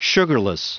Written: sugarless

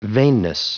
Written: vainness

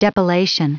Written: depilation

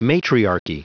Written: matriarchy

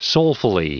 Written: soulfully

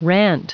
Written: rant

